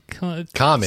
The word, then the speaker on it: Comite: I don't know how to say it. It's Comity? – Comity. – C-O-M-I-T-E. Comity?